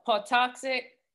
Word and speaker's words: Patuxet: Okay, so him, um, Squanto, and a uh, Patuxet tribe arrived. Am I saying that right, Griff? I know you, you, you are.